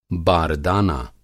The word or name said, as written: बारदाना